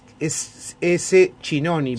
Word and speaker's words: Schinoni: Se escribe S-Chinoni, pero se pronuncia Schinoni.